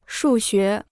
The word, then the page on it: 数学: shù xué